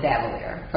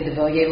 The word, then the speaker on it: Davillier: I pronounce it Davillier. For business, I say Davillier most of the time, because when people don't know me and they see it written, that is the actual name.